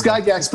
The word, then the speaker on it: Gygax: Gygax, but or is it Gygax? I can never remember. – It's Gygax, but don't worry. He's passed away now, so he'll take it either way.